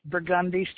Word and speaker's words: Burgundy: It's Burgundy Street, not Burgundy.